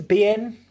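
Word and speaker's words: Biehn: Okay, so it's got Brittany Murphy as Jodie Markin, Jay Moore as Leonard Marston, Gabriel Mann as Kenny Ascot, Michael Bain. Is that how you say his name? Is it Bain? – Bien, Michael Bien. – Oh, Bien.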